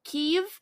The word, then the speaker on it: Kyiv: You pronounce the city Kyiv, but when you're pronouncing the entity Kievan Rus, you say Kiev instead of Kyiv.